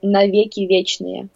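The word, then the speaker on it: навеки вечные: It came out Vicky Vichnie, or how do you say that? – Yeah, but we say it's right, but we have to add na at the beginning. So it will be Navieki Vichnie.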